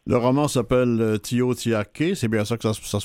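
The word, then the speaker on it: Tiohtià:ke: En fait, on remplace le T par un J, fait que ça fait Joe Jagi.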